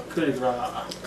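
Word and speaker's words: coup de grâce: Oh yeah, you can, that's good, Cope de grace. – Cope de grace. – Coup de grace. – Coup de grace, yeah.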